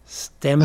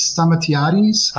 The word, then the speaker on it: Stamatiades: Correctly, the Greek way is stamatiades. – Ah. – But in English, you say stamatitis.